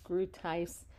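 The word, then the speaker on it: Groothuis: It's Douglas Grootheis, and it's spelled Douglas, D-O-U-G-L-A-S, like Douglas. Last name is spelled Groot, like G-R-O-O-T, Heis, H-U-I-S.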